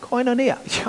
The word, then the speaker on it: koinonia: The first syllable is pronounced koi, like the fish. Got it? No, like no. Knee, like knee. And like Okay, so you got it? You got it? It's koi. Koinonia.